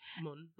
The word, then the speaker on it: Munn: There's like Olivia Munn. Is that how you say her name? – Yes. Munn.